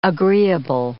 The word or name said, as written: agreeable